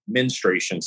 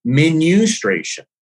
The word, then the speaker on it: menstruation: But this guy was like, I need you to pronounce, I've always said menstruation cycle. So he's like, no, menustration. And I'm like, menustration? Like, that doesn't even sound right to me.